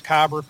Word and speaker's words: Kyber: Is that Kabar Core? Is that right? Am I saying that right? Kabar? – Kyber. – Kyber.